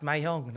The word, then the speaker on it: mahjong: I just learned it's pronounced Mahjong, not Mahjong.